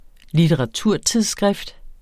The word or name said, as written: litteraturtidsskrift